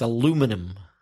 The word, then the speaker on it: aluminum: In the States, aluminum. Aluminum. The stress changes to the second syllable and the pronunciation. Aluminum. – So there are five syllables in the British pronunciation of aluminium. – But there's only four syllables in the American pronunciation, aluminum.